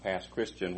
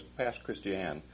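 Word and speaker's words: Pass Christian: We learned it was past Christiane, not past Christian. When you get there and you start calling it past Christian, they will quickly correct you. It's past Christiane, and even though it's spelled like Christian.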